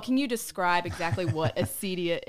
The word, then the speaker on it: acedia: So you start off in the book talking about the sin of acedia.